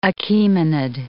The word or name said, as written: Achaemenid